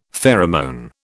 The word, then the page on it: pheromone: British English